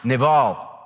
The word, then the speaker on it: Nabal: The fool, N-A-B-A-L is the Hebrew word, is pronounced Naval.